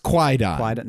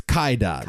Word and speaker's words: Kwaidan: I apparently it's not pronounced Kwaidan. – No. Kaidan? – It's Kaidan.